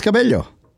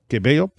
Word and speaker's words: Cabello: Performers included Alicia Keys, the Backstreet Boys, Billie Eilish, Billy Joe Armstrong of Green Day, Camila Cabello, and did I pronounce that right, or is it Cabello? – I think it's Cabello. – Cabello. Mm-hmm.